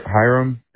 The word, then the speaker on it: Hiram: Hiram. H-I-R-A-M.